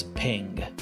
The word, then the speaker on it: PNG: Some people pronounce it as ping, which is somewhat confusing because ping is something else.